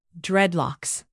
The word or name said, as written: dreadlocks